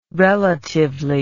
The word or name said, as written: relatively